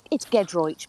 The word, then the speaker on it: Giedroyc: Is it Giedroyc? Did I say it right? – Listen, Emily, it's Giedroyc, but come on, you were close.